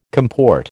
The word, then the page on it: comport: American English